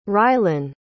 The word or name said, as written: rylynn